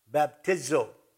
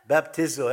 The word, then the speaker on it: baptizo: It's spelled like this, B-A-P-T-I-Z-O. Phonetically, this is how you pronounce it, baptizo. So that's the best I can do for you. Baptizo.